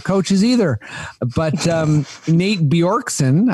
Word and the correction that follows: Bjorkgren: I think I'm pronouncing that right. – Bjorkgren. – Bjorkgren. Oh, yeah. Oh, it's Nate the Bjorkgren. S that's silent. How dare me? How dare I? – Bjorkgren.